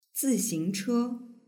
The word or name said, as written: zixingche